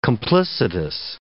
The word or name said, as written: complicitous